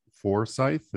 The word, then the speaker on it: Forsyth: And as you can see on the website here, you have Buckhead, Marietta, Canton, and then South Forsyth. Is that how you pronounce it? – Right. Correct. Yeah.